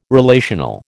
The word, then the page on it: relational: American English